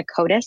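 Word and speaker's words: Codas: Yeah, on Twitter and Instagram, it's just my name, Rebecca Codis, spelled like I'm a musical coda with an S, because I just never end.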